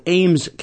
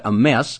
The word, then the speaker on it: Ames: Yeah, a little tiny town called Ames, Kansas. A M E S. Some people pronounce that a mess.